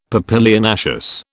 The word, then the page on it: papilionaceous: British English